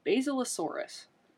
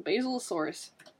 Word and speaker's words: Basilosaurus: And, okay, I think it's pronounced Basilosaurus. – Like the plant basil? – Like the plant basil. Basilosaurus.